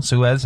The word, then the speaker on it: Suez: Suez, I pronounced that correctly? – Yes.